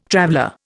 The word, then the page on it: traveller: Indian